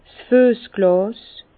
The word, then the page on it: 's Füessglàs: Bas-Rhin; Herrlisheim